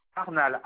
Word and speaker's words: Arnal: Arnal, ARNAL.